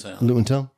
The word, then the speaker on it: Luton Town: Luton Town.